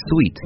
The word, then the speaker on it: suite: And the next two words that I hear sometimes that get mixed up between the two, suit S-U-I-T and sweet S-U-I-T-E。